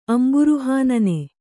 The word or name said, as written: ಅಂಬುರುಹಾನನೆ